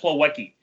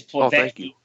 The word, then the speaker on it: Plawecki: Plavecki should be pronounced as I just pronounced it. Plavecki. That's a V sound. That's a V sound. It's not Plavecki. It's Plavecki from whatever roots that comes from in Eastern Europe or Scandinavian or Norwegian or whatever it is.